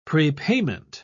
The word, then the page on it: prepayment: British English